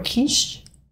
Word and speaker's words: kitsch: How do you say that word? Kitsch? K-I-T-S-C-H. – Kitsch. – Kitsch? – Yeah.